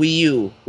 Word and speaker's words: Wii U: Nintendo has a new console also, which is called Wii U, which kind of rhymes with Weeaboo, but yeah, it's called Wii U.